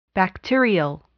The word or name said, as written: bacterial